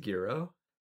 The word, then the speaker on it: gyro: Is it actually pronounced as gyro? – Yes, it's Greek.